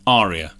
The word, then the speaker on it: aria: No, aria. – Aria. – Long A. An aria is like an award that you're absolutely never going to see.